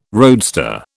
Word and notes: roadster: British English

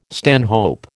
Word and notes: stanhope: American English

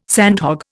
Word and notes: sandhog: Indian

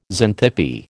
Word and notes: Xanthippe: American English

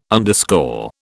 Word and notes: underscore: British English

